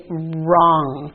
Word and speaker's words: wrong: And in this one, the W is silent, so we just say wrong.